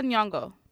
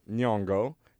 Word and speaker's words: Nyong'o: I'm not going to butcher her last name. – Lupita Nyong'o. – Nyong'o. There we go.